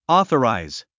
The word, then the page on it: authorize: ɔ’ːθəràiz; オーソラァイズ